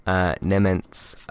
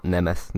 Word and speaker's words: Nemetz: That has this time been provided by somebody called Dave Nemeth. Not Nemeth, not my name. It's spelled very simply. N-E-M-E-T-Z. My name is N-E-M-E-T-H.